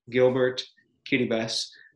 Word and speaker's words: Kiribati: The word Kiribati is actually the local pronunciation of Gilbert, Kiribati, because it was controlled by Britain for many years, and they called it the Gilbert Islands, at least the main group of islands in the western part of the country.